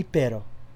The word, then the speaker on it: perro: P-E-R-R-O. – Translation, dog. – Dog. – You're saying it with a D? – Yeah. So you say it like with the double R.